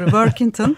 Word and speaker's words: Workington: Teşekkürler doğru telaffuz ettim değil doğru, mi? Doğru doğru Workington.